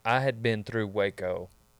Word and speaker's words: Waco: Like we leave College Station and we're going through Waco up. Yeah. And it's pronounced Waco.